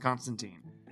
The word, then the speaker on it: Constantine: He remarks in the comic, in Hellblazer, that it rhymes with Turpentine. He hates when people call him Constantine.